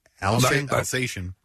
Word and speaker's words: Alsatian: He writes of one such jump with a female, how do you pronounce this dog? A-L-S-A-T-I-A-N? – Alsatian.